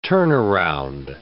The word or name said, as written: turnaround